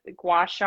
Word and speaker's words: gua sha: And then there's some other things, the gua sha, I never say that right.